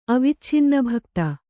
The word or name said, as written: ಅವಿಚ್ಛಿನ್ನಭಕ್ತ